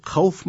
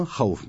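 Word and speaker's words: Havf (خوف): Orası dedi kavf mı, havf mı? Açıkça yazılı yani. Kur'an-ı Kerim öğrenmiş olsaydı. Havf. Ha, vav, fe. Havf. – Evet. – O şekilde okunur.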